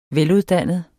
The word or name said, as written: veluddannet